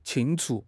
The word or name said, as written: 清除